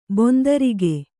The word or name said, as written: ಬಂದಾರಿಕೆ